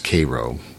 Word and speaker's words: Cairo: It's spelt Cairo, but it's pronounced Cairo if you're from southern Illinois.